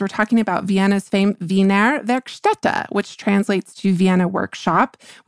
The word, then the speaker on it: Wiener Werkstätte: very nicely that we were mispronouncing the Wiener Werkstätte. So I believe that is the correct pronunciation. We are pronouncing it Wiener Werkstatt, which is not correct, but is how I've been pronouncing it forever, April.